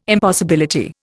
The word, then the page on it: impossibility: Indian